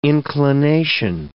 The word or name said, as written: inclination